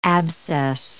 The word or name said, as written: abscess